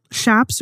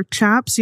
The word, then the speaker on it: chaps: But, you know, we did find two different pronunciations for it. So if you're okay with Shaps or Chaps, you know, mm-hmm. we're okay with it as long as J-Hope is wearing them.